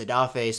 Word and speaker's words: Odafe: and that is Odafe, spelled O-D-A-F-E, but he says now he doesn't care, and people are going to have to learn it, so let's learn it.